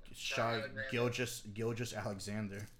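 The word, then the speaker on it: Shai Gilgeous-Alexander: Did you say Shaw Gilchrist? – What are they? Shaw Alexander Gilchrist? – No. Shaw Gilgis Alexander.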